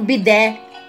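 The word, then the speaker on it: pide: pão bidé, se escreve pita, mas pronuncia-se em português bidé, que é a pronúncia em turco, né? Bidé, pidé.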